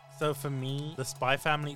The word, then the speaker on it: Spy x Family: It's not Spy X Family, it's Spy Family.